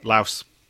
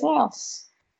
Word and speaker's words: Laos: Laos. Yeah. Wow. You don't pronounce the S. Lao then, whatever. Wow. Yeah. – Really? Oh, I always yeah. thought it was Laos.